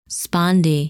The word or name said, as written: Sponde